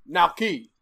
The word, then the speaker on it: Naoki: Because you're thinking of N-A-O, and if you just want to make it easier, just spell it N-O-W-K-I. – Naoki.